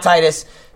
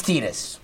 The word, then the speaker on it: Tidus: Yeah, it's Tidus. We all know it's Tidus.